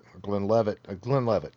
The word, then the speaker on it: Glenlivet: It's not like, I mean, like, you pay like $70 or $80 for like a Glenfiddich or like a Glenlivet or something like that. Glenlevet is how you pronounce that.